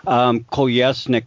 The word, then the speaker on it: Kolesnik: Kolesnik, but it's spelled K-O-L-E-S-N-I-K.